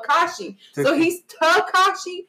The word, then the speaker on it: Tekashi: Tekashi. – Yeah. Is that how you say it? – Yeah. – Tekashi.